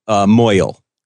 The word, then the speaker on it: mohel: Should I be saying mole? – No, the way they say it in the episode is moil. – Yes. – They're like putting in like an extra Y. The word is spelled M-O-H-E-L. – Okay. – So I would say moel, but I'm just like swallowing it a little bit. Like, I'm just saying it, you know, quickly.